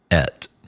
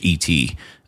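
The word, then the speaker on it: et (את): Phonetically, it's et, like in English, E-T, et.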